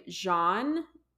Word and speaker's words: Jeanne: Tamla is invited to a woman named Jean. It's spelled like Jean, J-E-A-N-N-E, okay.